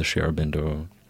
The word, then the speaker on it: Sri Aurobindo: Sri Aurobindo International Education Center, right? Did I say that right? – Yeah, that's right.